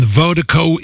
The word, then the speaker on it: Virdiko: And Vertigo is spelled V-I-R-D-I-K-O, pronounced Vertigo. That's V-I-R-D-I-K-O, D-I-K-O, pronounced Vertigo. It's the global music promotion out of Dallas, Texas. Big, big shout out, Vertigo Mobile.